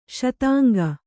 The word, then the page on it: ಶೀತಾಂಗಿ: śitāngi